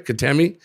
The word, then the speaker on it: Kitami: Well, the reason you might not be is because you were in Kitami. Am I pronounced that right? Kitami? – Yep.